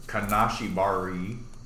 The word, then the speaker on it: Kanashibari: In Japanese, sleep paralysis is called kanashibara, or Kanashibari. – Credit for pronunciation.